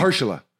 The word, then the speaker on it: Mahershala: Marshall Ali. – Yeah. – He actually played basketball. – Marshall, yeah. – What did I say? How do you pronounce it? – Mahershala, I think. – Mahershala.